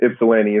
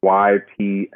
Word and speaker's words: Ypsilanti: And Ypsilanti is spelled funny, so people have a hard time pronouncing it. It's Ypsilanti. – You just said it so naturally. How do people mispronounce it? – Ypsilanti, or they emphasize the yips.